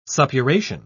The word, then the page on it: suppuration: British English